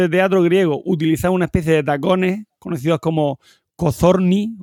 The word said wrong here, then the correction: coturnos: o cotorni, no sé cómo se pronuncia. – Coturnos, coturnos.